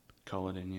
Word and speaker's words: Culloden: Culloden? – Culloden, yeah.